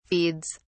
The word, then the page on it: feeds: British English